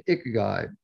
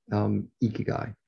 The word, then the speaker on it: ikigai: With apologies, sometimes I say it like a Canadian, ikigai, but I believe the correct pronunciation is in fact ikigai.